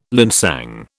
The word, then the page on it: linsang: British English